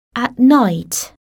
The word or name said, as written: at night